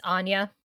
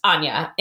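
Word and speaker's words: Áine: So since we're not in a visual medium, it's spelled A-I-N-E. But as I'm sure everyone knows, in Gaelic and Irish and Scottish and in Welsh, nothing is pronounced the way it's spelled. – No. – So this is pronounced Anya, and she is the goddess of wealth, prosperity, and luck.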